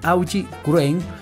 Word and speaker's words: Augie Wren: un cuento de Navidad de Aubrey Wren, de Paul Auster, lo voy a pronunciar mejor, mi inglés no es tan sofisticado como quisiera, es el cuento de Navidad de Augie Wren, de Paul Auster, y también el cuento Navidades de Iván Ewes.